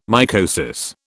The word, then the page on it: mycosis: British English